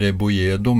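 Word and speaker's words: Rebolledo: Hans morfar var fra Mexico, der han mellomnavnet Rebojedo med to L-er i midten som uttales med J.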